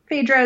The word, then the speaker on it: Phaedra: Is it Phaedra? – Phaedra is fine. Phaedra also sometimes. I mean, yeah, pronunciation of ancient names is not an exact science.